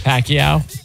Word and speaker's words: Pacquiao: Pacquiao.